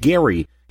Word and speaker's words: Gerry: Not only has he lost his political dignity in history, but Gerry lost the hard G in his name, and gerrymandering is now an international term for a crime against democracy.